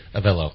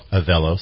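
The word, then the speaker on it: Avelo: But my sons just took Aveo. Is that how you say it? – Avello. – Avello, sorry.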